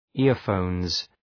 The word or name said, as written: earphones